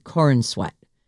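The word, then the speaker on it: Corenswet: It's spelled C-O-R-E-N, corn, S-W-E-T, corn sweat, which sounds like corn sweat, which is kind of at least it's not super ordinary.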